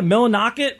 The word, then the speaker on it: Millinocket: Yeah, Millinocket, I think is how they say it.